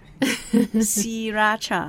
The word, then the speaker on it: Sriracha: Even we spell it out S-R-I, but the R is not pronounced. It's something in Thai language that we call is a false cluster. – Wow. Sriracha.